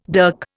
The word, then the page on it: dirk: Indian